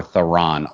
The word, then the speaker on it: Theron: So for the main course, we are going to be doing a discussion on The Old Guard, starring Charlize Theron, or Theron. A lot of people say her name funky. You ever notice that? – Yeah. I watched a couple of videos to figure out like how she pronounces it. – Yeah. – And it could go either way. – She says it both ways, right? – Yeah.